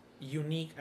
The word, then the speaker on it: UNYC: But basically, Unique, it's UNYC. There's no acronym. It's just pronounced Unique as the word UNIQUE, which is to be set apart.